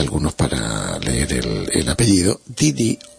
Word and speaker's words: Ndidi: El nombre de la señora se escribe N-D-I-D-I-D-D-O. Si lo buscan en internet, escriben eso. N, ¿no? O sea, N no se pronuncia. N-D-D, ¿no? D-D-O-N-U-K-U-L-U.